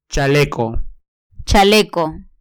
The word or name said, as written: chaleco